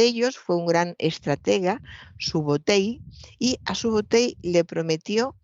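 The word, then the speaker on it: Subotai: Fíjese la promesa de, su, eh, creo que lo he dicho mal, de Subotei a Genghis Khan cuando se unió a, él. él. Subotei le prometió esto,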